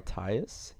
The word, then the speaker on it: Tyus: Uh, I don't know if I'm saying his name right, but Tyus. Tyus. – Tyus. I don't know. T Y U S. I'm pretty sure it's Tyus.